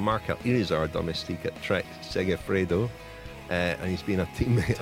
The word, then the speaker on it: Segafredo: Is that how you pronounce Segafredo.